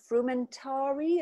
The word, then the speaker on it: frumentarii: And the book is called Legions of the Moon, and it's about two Roman frumentarii, is that how you pronounce it? – No, that's excellent, yeah.